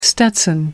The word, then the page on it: stetson: American English